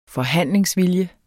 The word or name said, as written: forhandlingsvilje